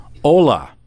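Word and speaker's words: hola (עולה): The Hebrew word for burnt offering is hola. Now we all know that in Spanish is hello, but it's pronounced the exact same way, has nothing to do with hello.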